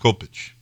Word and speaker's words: Kopyc: But that's the Y sound. In Polish, it's pronounced Kopecz with an accent over the C. It's not been shortened either. The headstones in Poland still have K-O-P-Y-C with an accent over it.